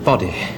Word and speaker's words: body: Tym bardziej dziwi mnie, że Polacy tak rzadko używają właśnie tej wymowy tego słowa, tej brytyjskiej body.